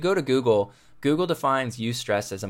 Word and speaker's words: eustress: There's distress, which we're all pretty familiar with that word, and then there's something called eustress, and it's spelled E-U and then stress. So eustress is how you pronounce it.